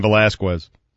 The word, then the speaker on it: Velasquez: Cain Velasquez.